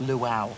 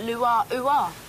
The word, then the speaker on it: luau: Yeah, I think you'll find it's pronounced luau. – Yeah, but it's spelt lua-ua. – But it's spoken luau. It's the Hawaiian pronunciation.